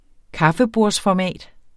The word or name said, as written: kaffebordsformat